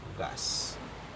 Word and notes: cas: Aneityum

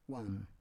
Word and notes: wam: Roon

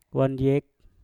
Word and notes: wandyek: mpur

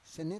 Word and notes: sene: Roon

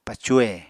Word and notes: pacuhe: Apatani

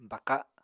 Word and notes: baka: Apatani

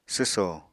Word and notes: swso: apatani

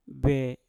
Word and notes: be: Mpur

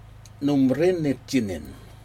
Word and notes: nombre nitjinin: Aneityum

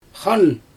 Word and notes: xaan: Xyzyl

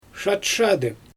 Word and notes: ʃatʃadɯ: Xyzyl

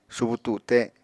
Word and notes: subu tute: Apatani

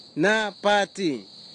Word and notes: napati: Kwamera

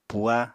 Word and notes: puha: apatani

